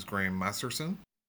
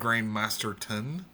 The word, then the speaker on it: Masterson: Yeah, and one of the audiobooks, the person narrating it got his name wrong at the start, and they said Graham Masterton.